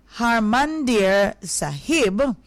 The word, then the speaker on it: Harmandir Sahib: So, September 1 has witnessed notable historical events such as the installation of the Adi Granth at Hamnadir Sahib, let's get that right, Harmandir Sahib, the holy scripture of sixth.